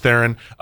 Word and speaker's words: Theron: Just so it's clear, it is pronounced Theron, which I learned this morning. Like, for my entire life, it's been Theron. Like, I've always pronounced it Theron. And it is Theron.